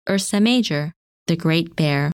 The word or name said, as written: Ursa Major